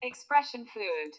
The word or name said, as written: Expression fluid